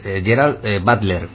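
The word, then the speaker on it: Gerard Butler: La respuesta correcta es Gerald Butler, creo que se pronuncia así.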